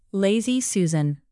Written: lazy susan

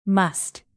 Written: must

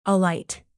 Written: alight

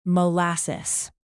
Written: molasses

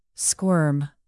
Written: squirm

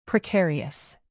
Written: precarious

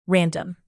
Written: random

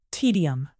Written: tedium

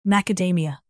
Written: macadamia

